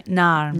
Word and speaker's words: Naarm: N-A-A-R-M. – Narm.